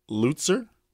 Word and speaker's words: Lutzer: Bernie Lutzer? – Yes.